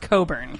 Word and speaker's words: Cockburn: C-O-C-K-B-U-R-N. – Cockburn? I could see why she changed it. – That could also be Coburn. – It is pronounced Coburn.